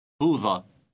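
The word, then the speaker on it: بوظة: Note the final silent T pronounced a. – بوضة.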